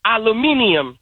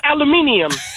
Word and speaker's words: aluminium: It's you know, it's how they say aluminum in Britain. They say aluminium. They pronounce everything. It's aluminium. Like, it's aluminum foil.